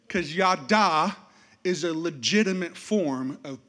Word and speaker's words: Yadah: Everybody say Yadah.